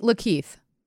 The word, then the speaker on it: Lakeith: It's a thriller comedy starring Daniel Craig and Lakeith Stanfield. I don't think I said his first name. – I think Lakeith. – Oh, Lakeith. Great. – Yeah, I think you said it right.